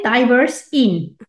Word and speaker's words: DiverseIn: So first of all, DiverseSign, is that the right way to pronounce it? – We call it DiverseIn. It's a little bit short version of a little bit diversity and inclusion. Mm-hmm. So that is where it's coming from, DiverseIn.